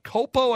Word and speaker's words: Culpo: It's got a possible 49ers star, possible Super Bowl winner, Christian McCaffrey, and Olivia Culpo, I believe is how you pronounce her last name. – Yep.